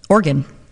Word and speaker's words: Oregon: O-R-E-G-O-N, Oregon. – That's not gone. It's gun. – Oregon. – Oregon. Like you're playing the organ.